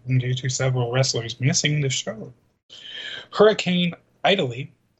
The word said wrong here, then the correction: Idalia: Now, I understand that it's Idalia, but they misspelled it, so I mispronounced it.